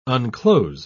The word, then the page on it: unclose: British English